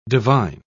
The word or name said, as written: divine